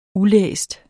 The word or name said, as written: ulæst